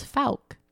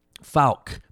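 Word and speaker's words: Fouke: And we found out how to say it. It's Falk. – Falk.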